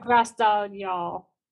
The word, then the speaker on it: Fagradalsfjall: How do you pronounce the name of this volcano? – This one is actually not too bad. It's Fagrastai. It's still hard to pronounce. – You're telling me you can't pronounce it? – It's okay. Yeah. – It looks like Fagradalsfjall, something like that. – Fagrastaljall. – That sounds better.